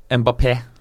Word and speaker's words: Mbappé: Det er en sånn greie over én, så du uttaler én. Mbappé. Ja. Så det legges vekt på E1.